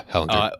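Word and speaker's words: Ehlinger: Yeah, Ellinger.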